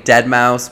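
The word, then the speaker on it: Deadmau5: I think Deadmau, I think the S is silent or whatever. – Well, it's a 5, actually.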